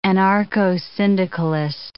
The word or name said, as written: anarcho-syndicalist